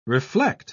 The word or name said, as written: reflect